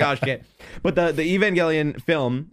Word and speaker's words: Evangelion: I've been calling it Evangeline. Apparently it's called, like, Evangelion.